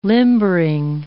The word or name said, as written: limbering